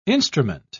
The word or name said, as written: instrument